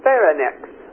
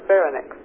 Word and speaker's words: pharynx: But it says pharynx, like almost need another syllable there, pharynx.